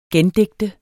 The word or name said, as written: gendigte